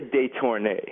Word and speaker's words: Detornay: Am I pronouncing all that? – I say Detornay.